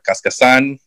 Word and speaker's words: Cascasan: But as I'm getting older and I'm trying to reclaim my name, I honestly don't know which way is the right way to say it, if it's Cascasan or Cascasan, so mm-hmm.